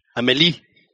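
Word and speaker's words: Amélie: Or Amelie, or I don't, I don't know how to pronounce Am- it. – Amelie. – There you go.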